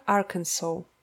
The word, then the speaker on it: Arkansas: Оно имеет на конце буквы «A» и «S», которые вроде бы как и можно было бы прочитать как-нибудь «Арканзас», но его произношение «Arkansas».